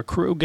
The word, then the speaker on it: Krug: Uh, at Michael underscore Krug.